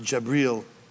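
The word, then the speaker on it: Jibril: But in Arabic, it's pronounced Jabril.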